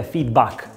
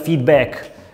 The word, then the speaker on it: feedback: Ecco, attenzione, ho detto feedback perché in inglese la pronuncia corretta è feedback, come Mac, non feedback.